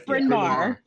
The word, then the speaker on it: Bryn Mawr: It's like that college I can never pronounce. Bryn Mawr. How do you pronounce that? Is it Bryn Mawr? – It's Bryn Mawr. Yes. – Okay, but it looks like Bryn Mawr.